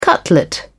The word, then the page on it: cutlet: British English